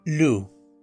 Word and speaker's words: Lug: Básicamente, es hijo del dios Lu, que se escribe Lug.